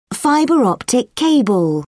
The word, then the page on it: fibre optic cable: British English